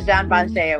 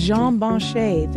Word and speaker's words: Jean Banchet: You recently won, let me see how to pronounce this. This is Jean Banchet. – The Jean Banchet Awards. – Jean Banchet, thank you.